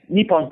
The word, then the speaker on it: Nippon: We also were what is it called again? – Nippon TV. – Vipom? How do you spell it? – NIPPON. Nippon TV.